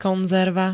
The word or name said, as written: konzerva